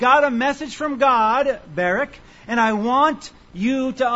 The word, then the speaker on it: Barak: Barak, I suppose, if you'd like to pronounce it that way. But Barak, probably the emphasis is on the first syllable.